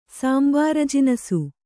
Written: ಸಾಂಬಾರಜಿನಸು

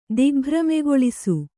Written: ದಿಗ್ಭ್ರಮೆಗೊಳಿಸು

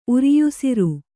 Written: ಉರಿಯುಸಿರು

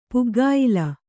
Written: ಪುಗ್ಗಾಯಿಲ

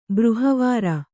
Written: ಬ್ರಿಹವಾರ

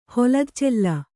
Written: ಹೊಲದಚೆಲ್ಲ

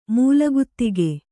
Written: ಮೂಲಗುತ್ತಿಗೆ

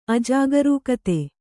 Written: ಅಜಾಗರೂಕತೆ

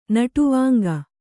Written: ನಟುವಾಂಗ

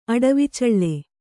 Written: ಅಡವಿಚಳ್ಳೆ

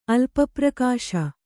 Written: ಅಲ್ಪಪ್ರಕಾಶ

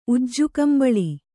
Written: ಉಜ್ಜುಕಂಬಳಿ

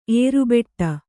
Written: ಏರುಬೆಟ್ಟ

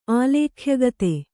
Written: ಆಲೇಖ್ಯಗತೆ

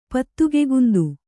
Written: ಪತ್ತುಗೆಗುಂದು

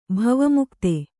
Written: ಭವಮುಕ್ತ

